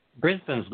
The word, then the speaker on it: Brisbane: Is it Brisbane or Brisbane? How are we pronouncing that? What's the correct pronunciation? – Brisbane's good. – Brisbane. All right.